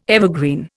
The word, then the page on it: evergreen: Indian